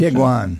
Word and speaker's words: Tiguan: Is it Tiguan? Is that what they pronounce? – Tiguan. – Tiguan.